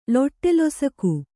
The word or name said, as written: ಲೊಟ್ಟೆಲೊಸಕು